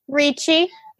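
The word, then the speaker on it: Ricci: Ritchie.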